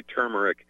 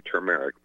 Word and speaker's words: turmeric: But, yeah, so there's, it's, you know, some people say turmeric, some people say turmeric.